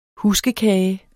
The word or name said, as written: huskekage